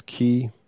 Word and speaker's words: quay: So that means you're going to need to drop your anchor off and then come up to quay. I've been corrected. That's key. I wish they'd just spell it key instead of Q-U-A-Y, which to me says quay.